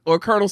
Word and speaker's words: colonel: The one or Colonel Sanders of KFC. – KFC. – No, but anyway, it's spelled like C-O-L-O-N-E-O. – Yeah, like Colonel Abrams. – And like yacht. It doesn't have a K in it.